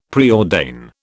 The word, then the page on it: preordain: British English